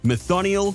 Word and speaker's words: Methionyl: It's the technical chemical name for the protein chitin, and this is how you say the start of it. Methionyl, threonyl, threonyl, glutenamine, leginyl.